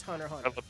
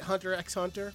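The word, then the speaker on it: Hunter × Hunter: Um, Hunter Hunter, I'm calling it Hunter X Hunter. Oh yeah, no, it's Hunter Hunter.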